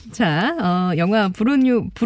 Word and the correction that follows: 부르고뉴: 브루뉴, 부르고뉴.